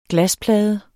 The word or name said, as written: glasplade